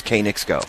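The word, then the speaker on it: Knicks Go: I was schooled by Pat Cummings on how you pronounce this horse's name. It is K-Nick's Go.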